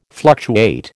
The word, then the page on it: fluctuate: American English